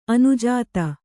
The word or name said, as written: ಅನುಜಾತ